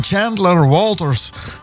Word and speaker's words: Chandler Walters: En wij kwamen daar als allereerste kwamen we daar tegen Chandler Walters. Zo moet ik het uitspreken. En je schrijft het als W-A-L-T-E-R's.